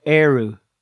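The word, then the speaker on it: Ériu: And then finally, they meet a goddess called Eru. She says, I am the goddess of this land. Name it after me. And her name is spelled E-R-I-U.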